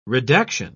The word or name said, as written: redaction